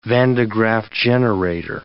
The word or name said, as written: Van de Graaff generator